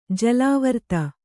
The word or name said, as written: ಜಲಾವರ್ತ